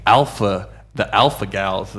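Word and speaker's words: alpha-gal: It's got something to do with the alpha gals.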